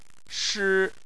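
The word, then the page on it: shi: tone 1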